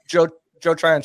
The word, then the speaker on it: Joe Tryon: Vita Vey, you got John Tyrone, right? – Joe Tryon Shrinka.